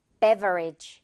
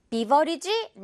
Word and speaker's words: beverage: 자, 그런데 발음 기호를 보면 이게 에, 에 발음이다 보니까 이 부분을 어떻게 처리해야 한다? 배라고 해줘야 돼요. Beverage. 비버리지? No, no, no.